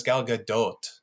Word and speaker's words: Gal Gadot: I hear people like, it's actually pronounced Gal Gadot. But then when you hear her in interviews, she has an accent. And I'm pretty sure it's not dot. She says Gal Gadot.